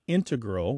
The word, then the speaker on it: integral: These days, you mainly hear integral, with the stress on the first syllable, among mathematicians and specialists in scientific fields that use a lot of math.